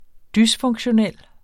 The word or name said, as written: dysfunktionel